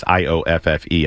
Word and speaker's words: Ioffe: I'm pronouncing her name that way. It's Ioffe.